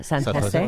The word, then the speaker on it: San Jose: We've been pronouncing San Jose wrong. – Obviously so. It sounds so much nicer when he says it. – San Jose.